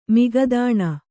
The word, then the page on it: ಮುಗದಾಣ: mugadāṇa